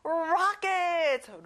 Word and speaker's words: rocket: O 발음이 아니라 어 발음이에요. Rocket.